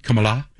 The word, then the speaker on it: Kamala: It's pronounced Kamala.